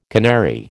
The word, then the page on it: canary: American English